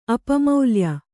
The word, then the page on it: ಅಪಮೌಲ್ಯ: apamaulya; Kannada